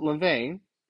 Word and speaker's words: LaVey: So this whole New Age system that we're looking at, which is premised on Satanism, according to Anton LaVey, butchering his name, let me look at it one more time just to make sure. Yeah, Anton LaVey, L-A-V-E-Y, if you guys are interested in looking him up, he actually stated, this is from the Church of Satan.